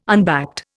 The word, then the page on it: unbacked: Indian